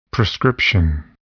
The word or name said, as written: prescription